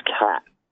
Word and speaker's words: khat: K-H-A-T. – Well, the real pronunciation in Somali is K-H-A-T, is KAT, right?